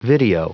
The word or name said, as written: video